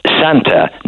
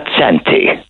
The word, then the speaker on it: Santa: The correct pronunciation is Santa, not Santy. Where did that ever come from? – I don't know, man. I guess you got to... – Man, I'm telling you, man, it's Santa. – Says, but... – Not Santy. – But who says that it's Santa? – Because it's spelled S-A-N-T-A, not S-A-N-T-E. Santa. – But I would spell it S-A-N-T-Y.